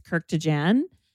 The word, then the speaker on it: Kurkdjian: We're going to be talking about Fleur Missing Person, Maison Francis Kirkwood. Oh, how do you say this one? Help me out. – Maison Francis Kirkdjian, Baccarat Rouge 540.